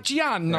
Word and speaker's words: Pacino: Is that how I properly say it? Is it Pacino? – Yeah. I'm using my hands. – Okay. Pacino.